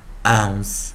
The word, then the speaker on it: ounce: 所以注意看 o u 组成双音 o o o，那 n c e 记得啊，我们讲结尾。一的时候，一是不发音，所以 n c ens ens ens 跟老师念 ens ens ens ounce ounce ounce 好，然后我们先看啊，那 bounce bounce bounce 那 ounce 它本身也是个字啊，ounce ounce ounce，然后我们来看最后一个 p p，我们就念。